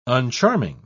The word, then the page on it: uncharming: British English